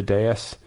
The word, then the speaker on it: dais: So it's like Kamala Harris, Ted Lieu, Adam Schiff could all be on the dais. Is that how you pronounce that word? – Yeah. – The dais? – Yeah.